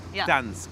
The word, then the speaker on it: Gdańsk: Gdańsk, Gdańsk. Okay, aber das ist der original polnische Name. – Ja. – Gdańsk. Man spricht nur das D, oder? – Nee. N mit Strich.